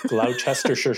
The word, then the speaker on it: Gloucestershire: Yeah, no, that's how it's said. Gloucestershire.